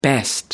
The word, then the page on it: best: British English